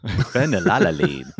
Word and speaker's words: phenylalanine: Caffeine, phosphoric acid, petrol, citric acid, phenylalanine. – Ooh, say that again. – Phenylalanine. – I'll say it right. – Phenylalanine. – That's better.